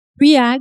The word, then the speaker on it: 3HAG: Je sais pas si je le dis bien. Triag, oui, on dit en anglais, triag, oui.